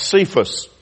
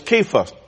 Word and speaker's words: Cephas: I'm going to call you," and we usually hear this name pronounced Cephas. However, actually that C should sound as a K, like we would say cat or catastrophic or some of those other words. It sounds as a K sound, so his name was Cephas or Cephas.